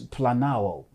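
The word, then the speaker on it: planao (πλανάω): The specific Greek term used for deception, the verb here, is planao in Greek. It almost sounds like the word plan.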